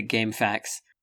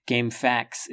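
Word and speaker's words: GameFAQs: As a little aside here, I always pronounced it Game Facts, and I enjoyed pronouncing it Game Facts because it sounds similar to Game Facts,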